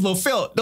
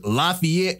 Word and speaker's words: Lafayette: Tell this man, look, man, everybody from Valley, Lynette, Waypoint, like all this, it's LaFelt. Don't nobody pronounce this Lafayette or LaFayette.